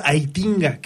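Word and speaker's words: Heitinga: Sí, se trata de el señor Aitinga, que eh, así se pronuncia Adrián,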